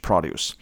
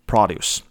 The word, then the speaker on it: produce: 或者是写 fresh produce 啊。如果大家要考多益的话，哈，这个字还蛮常会出来的 produce，这个时候念成的是 produce。那如果是动词的话，它才念成 produce。